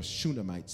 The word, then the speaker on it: Shunammite: But for those of you who do want to spell it, it's S-H-U-N-A-M-M-I-T-E. Shunammite. Shunammite.